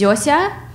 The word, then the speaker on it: Zosia: Like, try to say Zosia. Nope. So there was someone who would stop her on the street and be like, "Oh, what's the dog's name?" And she'd go, "Zosia."